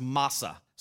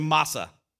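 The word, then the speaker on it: massa: The word oracle here is a Hebrew word that's pronounced masa. Say masa.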